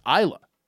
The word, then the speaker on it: Isla: I also think people should know how to pronounce Isla. – Yeah. – It's spelled like island.